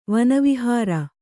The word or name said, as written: ವನವಿಹಾರ